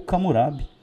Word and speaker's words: Hammurabi: E da Babilônia, a gente destaca o seu principal governante, que é Hammurabi, ou Camurabi, né, na pronúncia da região.